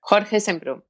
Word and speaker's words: Jorge Semprún: Jorge Semprún.